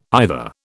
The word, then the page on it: either: British English